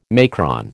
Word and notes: macron: American English

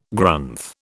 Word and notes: Grunth: British English

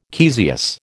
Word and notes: caesious: American English

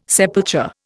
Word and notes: sepulture: Indian

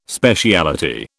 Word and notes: speciality: British English

